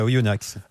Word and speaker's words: Oyonnax: Oyo Automobile, tout à fait, à Oyonnax. – Allez, je ne crois pas qu'on prononce le X. Je me suis fait engueuler sur Twitter récemment parce que je l'ai prononcé. Il ne faut pas le prononcer.